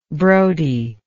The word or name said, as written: brodie